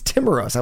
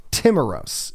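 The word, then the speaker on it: Timiraos: And that's precisely what um, Nick Timoros, that's what it is. It's Timoros. I was saying it's Timoros, is how you say it.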